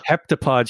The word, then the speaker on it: Heptapod: The Heptapod, Heflopod? I'm never going to be able to pronounce that. – Heptapod ship is like angular and almost welcoming, and it's offering like a tool to unite humanity.